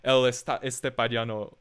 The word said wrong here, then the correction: El Estepario Siberiano: How do you say it? El Estepario Siberiano, right?